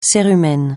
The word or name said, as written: cérumen